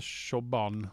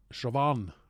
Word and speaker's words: Siobhan: Ja, men det, hon heter inte Siobhan. Hon heter Siobhan.